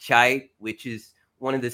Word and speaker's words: Cheit: Chite.